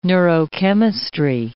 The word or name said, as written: neurochemistry